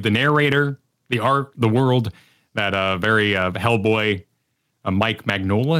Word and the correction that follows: Mignola: is that how you say it? – Mike Mignola? – Yeah.